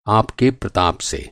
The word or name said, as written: प्रताप